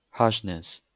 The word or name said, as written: harshness